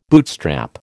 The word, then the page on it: bootstrap: American English